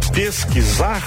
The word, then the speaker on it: pesquisar: É o mesmo caso de pesquisa, você está ouvindo o som do S, pesquisa, e vai pronunciar pesquisar, aí já o som do S já fica como som de Z, mas escreve com S, né?